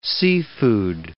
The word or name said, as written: seafood